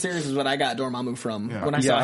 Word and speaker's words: Dormammu: So I've had a couple people on Twitter ask me how to say Dormammu's name. I've always pronounced it Dormammu. I've heard it. I've heard other people recently pronouncing it Dormammu. – Dormammu, yeah.